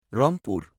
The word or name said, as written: Rangpur